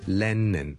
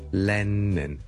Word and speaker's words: Lennon: Lennon，L-E-N-N-O-N，第一个系 L 音，所以唔好将佢读成 N 音，唔系 Lennon，系 Lennon，Len，Le，Le，Le，Le，Le，Le。 Len 后面嗰个先系 N 音，所以 Lennon 仲要两个 N 音添。 Lennon 第一个 L，Lennon，Lennon，Lennon，Lennon，Lennon，所以 John Lennon 大家练一练。 John 当然系 N 音味啦，又更加搞乱啦。 John Lennon，无论你读得几快都好，都唔可以变咗 John Lennon，唔系 John Lennon，一定系 John Lennon，John Lennon，John Lennon。